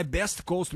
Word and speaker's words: Best Coast: Beast Coast, na verdade, né? é, a pronúncia é correta. Então, quase cinco anos após o lançamento do disco California Nights, a dupla de Los Angeles. Ah, é Best Coast mesmo, cara.